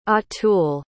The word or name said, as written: atul